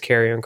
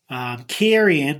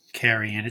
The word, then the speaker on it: Karrion: It's looking like the main event for most likely is going to be Finn Balor defending the NXT championship against Karrion Kross. What's your thoughts on that matchup? – Karrion, that's how you have to say his name. It's not Karrion. It's Karrion.